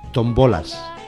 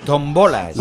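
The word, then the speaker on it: tómbolas: Tombolas. – tombolas. – Lo que hay en las fiestas, pero he cambiado la sílaba tónica. En vez de tombolas, tombolas.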